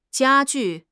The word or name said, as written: jia1 ju4